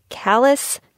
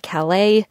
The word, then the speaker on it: Calais: A quick recap, if you only take a few names from this episode, it is Callis, not Calais, Charlotte, not Charlotte, and Wolcott, not Walcott.